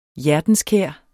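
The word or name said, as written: HJERTENSKÆR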